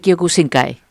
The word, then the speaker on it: Kyokushinkai: Karate Kyokushinkai.